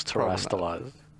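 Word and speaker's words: Terastallized: Um, terrestrializing. Am I saying that correctly? I believe it was terrestrialized. – Terrestrialized was the terrestrialized. – Yeah. There we go. – That sounds better.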